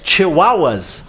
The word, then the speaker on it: chihuahuas: Yeah, it's chihuahuas. Ooh. Which, of course, as everybody knows, rhymes with Tijuana, doesn't it?